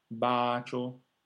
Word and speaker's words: bacio: Pensare a una lunga A, placido, placidi, bacio, baci.